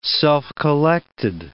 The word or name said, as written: self-collected